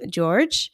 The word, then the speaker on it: Georg: No, George is okay. – Please correct me. – In German, it would be Georg, but it's okay.